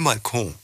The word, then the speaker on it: balcon: Ich entschuldige es immer mit meinem Französisch, weil auf Französisch sagt man le Balkon. Das heißt, man zieht es länger. Oh, das, und deswegen. Habt ihr das gerade gehört? Wie schön sie das ausgesprochen Le Balcon.